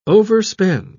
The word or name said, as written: overspend